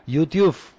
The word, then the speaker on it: YouTube: que es el YouTube.